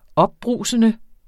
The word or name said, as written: opbrusende